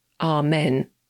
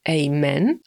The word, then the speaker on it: Amen: No a słowo Amen można wymawiać po angielsku na dwa sposoby. Najczęściej jest tak, że Brytyjczycy mówią Amen, a Amerykanie Amen, ale tak naprawdę obie te wersje można spotkać i w Wielkiej Brytanii, i w Stanach.